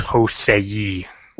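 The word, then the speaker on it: josei: I think Michiko and Hachin is like technically a Jose. – Or yeah. – How the fuck you pronounce that. – Yeah, you got it. – First one on the block. – Actually, it's Hosei.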